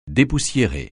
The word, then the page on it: dépoussiérer: francais